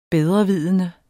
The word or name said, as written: bedrevidende